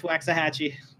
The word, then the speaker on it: Waxahachie: Waxahachie? – Yep. – Yeah, it's Waxahachie.